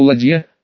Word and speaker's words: ладья: Маркуса больше всего удивило то, что ладья, с ударением на «о», так свою посудину именовали подранки, была сделана из липы, ведь катер-то мальчики из дубовых досок строили.